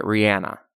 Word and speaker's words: Rihanna: But RuPaul says it right. And that's where I learned to say it, Rihanna. And I did a music video with her too. And she didn't even correct Rihanna.